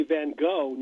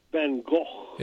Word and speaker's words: Van Gogh: Well, and they say Van Gogh, not Van Gogh. Van Gogh. I have a Dutch friend. He's always telling me I'm mispronouncing it, even when I try to pronounce it like he does. But it's Van Gogh.